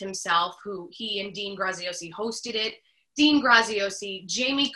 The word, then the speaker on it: Graziosi: Graziosi.